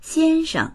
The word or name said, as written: xian1sheng